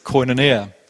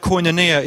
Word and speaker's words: koinonia: I'm quite sure that's how you pronounce it, but that's what I'm going to go with, koinonia.